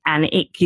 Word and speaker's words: Alnwick: Even from my area of the world where I come from, which is near Alnwick, which is spelled Alnwick. So you would pronounce it would be kind of Alnwick. It's actually Alnwick. You don't pronounce the W.